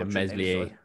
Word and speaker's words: Meslier: Um, you know, their Leeds goalkeeper Melia is, is how you say his name. – Yeah, Meslier. – Meslier, Melia.